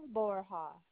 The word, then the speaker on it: Borja: Hi, my name is Mabel Borja, and I'm calling for roadside assistance. I'll give you the policy number. – No, I need your name. – Mabel. Mabel Borja. – Spell that last name, please. – B-O-R-J-A. B as in boy. O-R-J-A. – B-O-R-J.